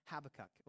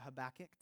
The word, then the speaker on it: Habakkuk: It's this tiny 3-chapter book called Habakkuk, or Habakkuk, depending on how you want to pronounce it.